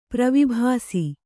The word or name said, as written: ಪ್ರವಿಭಾಸಿ